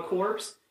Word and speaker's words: Corps: The P is silent. – Corps. – The P and the S are silent.